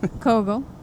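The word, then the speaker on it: Koegel: I like Kegels. I'm pretty sure that's not how it's pronounced. – That is not how it's pronounced. – Kegels hot dogs. – Kogel. – Kogel. Thank you.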